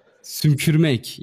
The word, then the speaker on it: sümkürmek: Sümkürmek in Turkish. sümkürmek. yeah, sümkürmek. Yeah, almost correct.